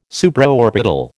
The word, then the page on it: Supraorbital: American English